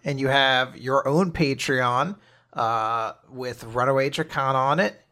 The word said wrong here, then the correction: Dracina: You got it mostly right. It's Runaway Dracina. It's like a Greek word or something that just means dragon, but fancy.